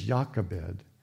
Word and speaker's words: Jochebed: But her name is Jochebed, and the first syllable, Yah, stands for Yahweh.